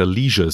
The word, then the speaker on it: Leisure: Wir einigen uns erstmal drauf, wir nehmen die britische Aussprache, ne? Also Leisure Suit Larry. Leisure. Amerikaner betonen es ja Leisure Suit Larry.